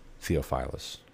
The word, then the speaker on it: Theophilus: Most Honorable Theophilus, or Theophilus, if you will, depending on how you prefer to emphasize or stress the syllables. Most Excellent Theophilus.